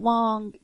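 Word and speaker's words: Wang: Oh, and for Garrett Wong, you know, we do want to say it's W-A-N-G, but he does pronounce it Wong.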